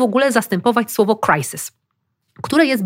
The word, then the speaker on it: crisis: Crisis, crisis, crisise. Serio. Nie. – No jest ponad 20 języków, wiesz, ja wiem, w całej Europie. No. – Crisis to jedyna poprawna wymowa.